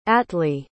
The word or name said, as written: atlee